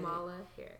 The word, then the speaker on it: Kamala Harris: I've done some research on this Kamala Harris lady. I don't know if I'm saying her name right. And honestly, Kamala Harris.